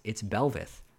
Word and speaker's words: Belveth: Funny story, I was actually saying Belvath a lot during one of the casts, and I got some feedback like, no, it's Belveth. But yeah, I need to pay attention to those new champions' pronunciations. Belvath. It's Belveth, I'm pretty sure.